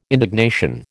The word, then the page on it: indignation: American English